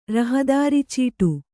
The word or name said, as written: ರಹದಾರಿಚೀಟು